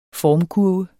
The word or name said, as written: formkurve